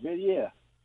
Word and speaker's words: Verrier: I'm going to butcher this up, sorry, but Verrier, is that how you say your last name, Bob? – Absolutely correct. Verrier.